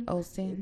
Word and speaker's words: Osteen: For a long time, I thought it was Joel Olson. I did. Joel Olson. – But is Joel Osteen? – Osteen.